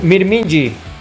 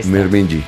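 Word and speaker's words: Mirminji (μυρμήγκι): Mirminji. Mirminji. Mirminji. – Mirminji. – Que la gente se lo aprenda. ¿Cómo se dice hormiga en griego? – Mirminji. – Mirminji. – Mirminji. Muy bien.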